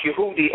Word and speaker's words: Yehudi: So you can't say Jew in Hebrew. It's pronounced Yehudi or Yehuda in English, pronounced Judah.